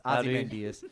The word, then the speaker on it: Ozymandias: In comics, how do you say that? – It's Ozymandias.